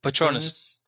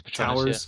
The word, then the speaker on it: Petronas: How do you pronounce it? – Petronas. I think it's Petronas.